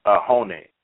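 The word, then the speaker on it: ajoene: It's A-J-O-E-N-E. A-J-O-E-N-E. But it's pronounced ahoni, which has potent antifungal properties.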